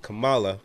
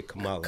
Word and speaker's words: Kamala: I love that Americans have to say Kamala. – Kamala. – Yes. – Oh, Kamala. Is it Kamala or Kamala? – I say Kamala, but. – Either way. – I only remember because it rhymes with Mamala.